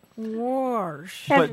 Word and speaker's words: wash: And it's really weird, and linguists have kind of puzzled over this, and the best explanation I've heard is just basically that your tongue sort of anticipates that SH sound, and then it kind of curls a little bit. Warsh.